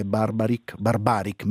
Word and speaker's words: Barbaric: Il brano che ascoltiamo insieme è Barbaric, meglio, l'accento lo mettiamo nel punto giusto.